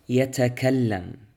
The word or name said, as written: يتكلم